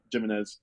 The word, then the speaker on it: Jiménez: And then it wasn't actually until I came here to Utah that somebody told me, like, no, your last name's Jiménez. – Yeah. – Like, oh, yeah, you're right. That's not just a Spanish pronunciation. That is my last name.